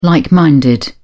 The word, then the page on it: like-minded: British English